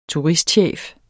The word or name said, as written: turistchef